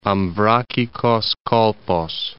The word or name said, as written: Amvrakikos Kolpos